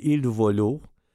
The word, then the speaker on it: Il Volo: Et il y a un trio, je ne sais pas si vous connaissez celui-là, il s'appelle Il Volo, I-L, en deux mots, I-L seul, et Volo, V-O-L-O, tout simplement.